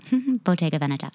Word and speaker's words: Bottega Veneta: And I'm really proud of myself because apparently I've been pronouncing it wrong and I just learned it. So Bottega Veneta.